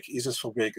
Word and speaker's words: Verbeek: You know, we say Isis Verbeek. Isis Verbeek is the right pronunciation.